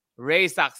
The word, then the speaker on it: Raczek: Raczec, see? – Yeah, so it kind of rhymes actually. Isaac, oh, Raczec.